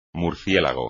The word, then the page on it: murciélago: Spanish